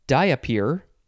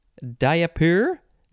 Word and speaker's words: diapir: The first word is, I think it's pronounced, diapir, diapir, diapir, D-I-A-P-I-R. It's almost diaper, but it's diapir, I think.